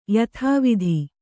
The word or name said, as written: ಯಥಾವತ್ತು